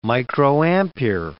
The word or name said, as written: microampere